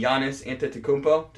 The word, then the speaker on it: Antetokounmpo: Giannis Antetokounmpo, did I even say it right? – Yeah, I think you got it close enough.